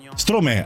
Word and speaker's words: Stromae: E allora sulle note di, non so se si dice Stromae, Strome, come si pronuncia, adesso lo chiediamo ad giornalista italiano che sta in Francia. Ciao, buongiorno, ben ritrovato. Come lo pronunciamo? – Stromae. – Ah, perfetto, fantastico.